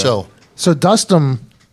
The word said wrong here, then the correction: Dostum: am I saying his name right? – Dostum. Dostum. Dostum.